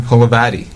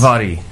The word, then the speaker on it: Holovaty: I'm here in Chicago for Rails conference, but I thought I'd take the opportunity to talk to a local developer, Adrian Holovaty. Did I say the right that time? – Holovaty. Yes. – Holovaty. Okay. We're in Chicago. Got to say it right.